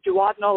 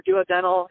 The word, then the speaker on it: duodenal: Okay, so we call them duodenal or duodenal. I hear people pronounce it both ways,